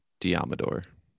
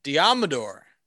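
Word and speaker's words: Deommodore: cornerback Diomodor Lenoir. Love that name. – It's pronounced like Commodore, but Diomodor. – Diomedor.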